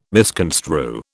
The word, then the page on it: misconstrue: British English